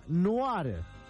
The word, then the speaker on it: noir: Acá Eliana me cuenta, en La Rioja se pronuncia noir. Es en francés, significa negro.